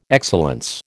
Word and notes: excellence: American English

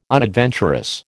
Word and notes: unadventurous: American English

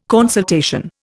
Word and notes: consultation: Indian

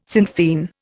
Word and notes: Sinn Fein: Indian

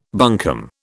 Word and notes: bunkum: British English

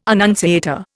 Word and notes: annunciator: Indian

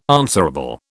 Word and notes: answerable: British English

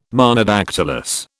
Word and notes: Monodactylous: British English

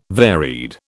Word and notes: varied: British English

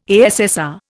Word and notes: ASSR: Indian

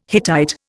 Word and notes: Hittite: Indian

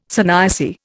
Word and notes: sannyasi: Indian

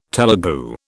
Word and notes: Telegu: British English

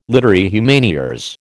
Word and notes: literae humaniores: American English

